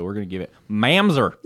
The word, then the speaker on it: Mamzer: To find it in the Orthodox Jewish Bible, I had to spell it as Mamzer, M A M Z E R. – Mamzer. – But in the Orthodox or in the Jewish encyclopedia is Mumzer.